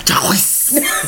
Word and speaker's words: Jacquees: Jacquees. How you pronounce it? – Jacquees.